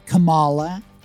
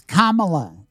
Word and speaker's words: Kamala: Not Kamala, not Kamala. It's Kamala, like Mamala or Bubala.